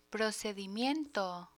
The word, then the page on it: procedimiento: Spanish